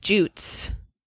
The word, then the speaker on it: Jutes: Her current boyfriend is named Jutes. – Jutes. – Oh boy. – Jutes, period. – How do you spell that? – J-U-T-E-S. – It's like Puss in Jutes.